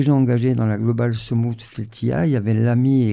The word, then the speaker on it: sumud: Je rappelle que Soumout, c'est ce mot fondamental qui s'écrit S-U-M-U-D, qui concerne les Palestiniens et qui nécessite plusieurs verbes pour le traduire en français.